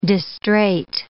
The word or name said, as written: distraite